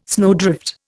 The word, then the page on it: snowdrift: Indian